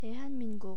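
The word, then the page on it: Daehan Minguk: Korean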